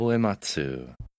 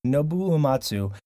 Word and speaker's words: Uematsu: Um, was it pronounced Nobu Amatsu? – Yeah. – Nobuo um, Uematsu. – Nobu Uematsu, uh, which we are all very jealous uh, this week in Japan.